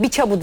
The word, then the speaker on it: Biçavut: Biçavut diye yazılıyor ama kendisi söyledi Biçavut diye okunuyor.